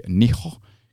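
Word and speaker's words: Nijo: It's N-I-J-O, I believe would be pronounced as Japanese. So it would probably be Niho Castle in Kyoto, Japan.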